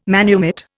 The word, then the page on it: manumit: Indian